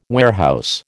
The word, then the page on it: warehouse: American English